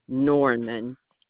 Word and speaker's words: Norman: E-L-E-S-T-I-N-E. – Right. Norman. N-O-R-M-A-N.